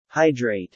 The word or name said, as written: hydrate